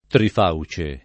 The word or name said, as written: trifauce